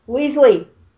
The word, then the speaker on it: Weasley: Shut up, Wesley! – Weasley. If you're not going to change the A... Say it right.